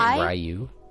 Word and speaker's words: Ryu: Isn't it Ryu? – Yeah, Ryu.